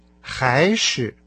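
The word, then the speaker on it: хайши (还是): Слово «или» по-китайски хайши. Ши – это хорошо знакомое нам с вами ши из словосочетания шибуши. Но в данном случае оно произносится с легким тоном. А перед ним стоит слово хай, второй тон, который вообще-то означает еще, кроме того. И то, и другое вместе – это или.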